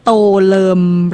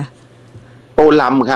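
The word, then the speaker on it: Tô Lâm: โตเลิมหรือโตลำคะโตลำ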